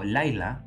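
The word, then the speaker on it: Laila (לילה): Ahora, la definición de noche lo pueden encontrar en el diccionario Strong, que es el número 3883, que se pronuncia en hebreo como Laila, que significa literalmente eh, medianoche, nocturno, noche, pero algo interesante, que significa alejándose de la luz.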